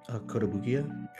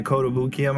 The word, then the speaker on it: Kotobukiya: Kotobukiya. – Yeah, Kotobukiya might have a kit.